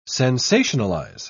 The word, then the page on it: sensationalise: British English